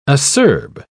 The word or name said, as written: acerb